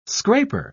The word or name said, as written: scraper